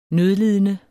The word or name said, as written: nødlidende